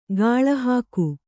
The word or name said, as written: ಗಾಳಿಹಾಕು